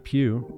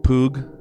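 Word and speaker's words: Pugh: It's not Pew. – Poog.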